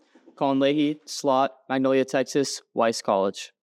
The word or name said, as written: Colin Leahey